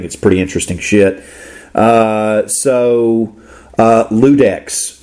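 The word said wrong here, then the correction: Iudex: track 9, if I said that right. – It's Udex. – Oh, Udex, excuse me. Udex. – It's Udex. Yeah, it's Latin for judge.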